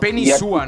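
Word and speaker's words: Penei Sewell: Ojo con este bro que se llama Penny Sable. Es un tackle ofensivo, viene de Oregon Ducks. – Penny Sua, ¿no? es la pronunciación correcta.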